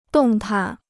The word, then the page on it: 动弹: dòng tan